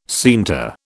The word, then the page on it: scienter: British English